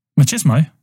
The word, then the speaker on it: machismo: In the post-punk years, Glasgow-based bands like Orange Juice reacted against the overt machismo. I don't even know how to say it. – Machismo. – Is that right? – Machismo.